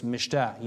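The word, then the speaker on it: mishtah (מִשְׁתֶּה): Notice the term feast, it's mishtah. Or I should say in Hebrew, it's mishtah. You usually pronounce the last syllable more hardly than the first.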